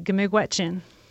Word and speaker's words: Gamigwetchen: How do you say thank you in Anishinaabemowin? – you would say Gamigwetchen. Okay, I'll try that. – Gamigwetchen.